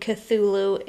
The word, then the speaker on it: Cthulhu: Which I read that pronouncing Cthulhu is more like a grunt. – Yeah, it's more like Cthulhu. It's very guttural and like Germany? – Very consonant rich.